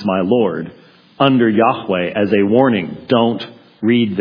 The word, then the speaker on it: Yahweh: Yahweh or Jehovah as we slaughter it in English. Could never have been pronounced Jehovah. Jehovah has too many syllables in it.